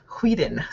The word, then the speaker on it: Whedon: Joss Whedon stuck it to me. – I love that it takes the body for all of you to actually say his name correctly. Finally. – Whedon.